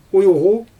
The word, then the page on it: ujɣu: Xyzyl